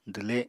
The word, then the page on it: dwle: apatani